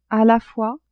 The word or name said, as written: a la fois